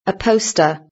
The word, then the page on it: a poster: British English